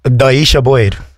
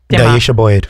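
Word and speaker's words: Dw i eisiau bwyd: Daisha Boyd. I'll tell you how it's spelt. – The Czech Republic. – It's not the Czech Republic. It is spelt DW space I space EISIAU space BWYD. Daisha Boyd.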